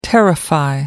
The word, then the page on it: terrify: American English